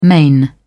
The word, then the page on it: main: British English